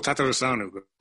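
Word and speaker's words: Tătărușanu: So maybe you could go ahead, give it a go, so we make sure we say it right. – Oh, it's still Tatarusanu. – Tatarusanu. Okay.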